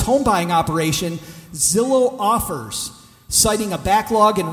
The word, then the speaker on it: Zillow: Are you guys familiar with Zillow? – Begins with a Z, rhymes with pillow.